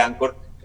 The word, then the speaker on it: Anchor: Anchor, sí, bueno, Anchor se escribe Anchor con CH, Anchor en inglés.